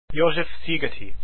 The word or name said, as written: Szigeti